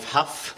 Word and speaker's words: Hough: So my name is Dave Huff, spelt H-O-U-G-H, but pronounced Huff as in rough, tough and enough.